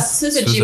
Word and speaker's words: Syzygy: Ja, Syzygy, mit einem harten S. Es wird Englisch ausgesprochen und Syzygy, okay.